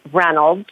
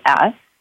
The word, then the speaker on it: Reynolds: Well, my name is Kristen Reynolds, like Reynolds Wrap, Reynolds. And the way to contact me or my staff would be Golden Ray Energy.